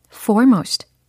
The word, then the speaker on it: foremost: F로 시작하는 단어인데, F-O-R-E M-O-S-T 라는 철자로 어, 발음이 어떻게 될까 한번 상상을 해보세요. F-O-R-E, for, M-O-S-T, most. 오, 정답. 빨리 말씀을 해보시면 foremost, foremost, foremost 이렇게 하시면 되는 거예요.